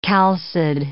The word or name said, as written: chalcid